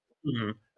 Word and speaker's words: Home: It's spelled Home, you're right. It's pronounced Hume, but everyone does that.